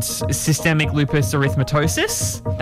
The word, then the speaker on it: systemic lupus erythematosus: She then was diagnosed with functional neurological disorder and systemic lupus erythematosus. – That's, wow, well done. That's a great pronunciation.